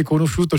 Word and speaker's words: conosciuto: C'est juste que pour prononcer conosciuto, il faut rajouter. Oui, tu supprimes le L, mais il faut mettre un I.